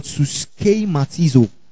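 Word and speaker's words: suskematizo (συσχηματίζω): Suskematizo. I'll spell it for you. S-U-S-C-H-E. The E is accentuated. Okay? What do Yoruba people call ami? Did you get that? Okay? Then M-A-T-I-Z-O. The O is also accentuated.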